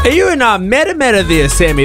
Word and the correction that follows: Matamata: Sorry, correct pronunciation, Matamata. I'm learning that because my parents called it Matamata growing up.